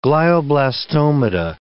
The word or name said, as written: glioblastomata